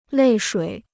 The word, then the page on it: 泪水: lèi shuǐ